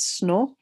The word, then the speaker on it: Snout: which doesn't seem to make sense until you realize how Snout would be pronounced in French. It would be pronounced as, sorry, as Snow and therefore the translators just made it easier to read in English.